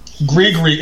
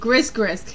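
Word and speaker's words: gris-gris: Grigri. It's spelled Grisgris.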